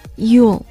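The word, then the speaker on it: you'll: Y-O-U apostrophe L-L Yule 就是这样一个连写、 mm-hmm.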